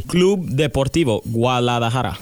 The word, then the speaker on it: Club Deportivo Guadalajara: Would you make sure I pronounce that correctly? – Club Deportivo Guadalajara. – Awesome.